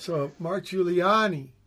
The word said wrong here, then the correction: Guiliana: No, Giuliana, because Giuliani is that plural of the clown in New York City, right?